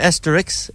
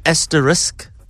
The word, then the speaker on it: asterisk: Suits that it's not an asterisk, it's an asterisk.